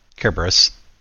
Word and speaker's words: Cerberus: I was also curious about the mention of, I know I always say it wrong, Cerberus. – Yes, Cerberus.